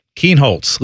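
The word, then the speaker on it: Kienholz: Keenholz, Lincoln Keenholz. – Keenholz, is that how you say it? – Yeah.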